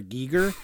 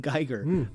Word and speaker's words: Giger: Giger. And I also realize now that I've been mispronouncing his name forever and ever. So I will no longer call him Geiger.